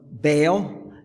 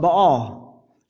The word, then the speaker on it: Baal: Jack gave one of the pronunciations of the name, Baal. Some call it Baal, and some call it Baal, whichever Hebrew way you want to approach it.